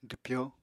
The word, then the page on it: dwpyó: apatani